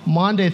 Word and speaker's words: Maundy: It's spelled M-A-U-N-D-Y, Monday, Thursday.